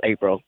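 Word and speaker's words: Aprol: It's spelled April. It's pronounced April. – Okay, but it's A-P-R-O-L.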